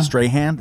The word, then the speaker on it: Strahan: Strahan. What's the guy's name? He's a really great actor. – Michael Strahan? – Strahan? Yeah, come on. It rhymes with Reihan.